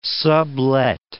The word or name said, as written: sublet